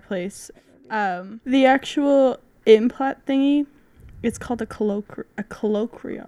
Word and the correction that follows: cochlear: I can't say it. – Cochlear? – Cochlear. That's how you say it.